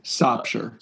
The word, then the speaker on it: Sopsher: Ishmael Sofer, too. Like, I think a year, or Sofer. How do you say it? Sofer? Sofer? – Sopsher.